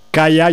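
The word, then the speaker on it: Kiah: Se llama Kaya, yo digo Kaya, se escribe Kia con K I latina A H. Digo yo que se pronunciará algo parecido a eso.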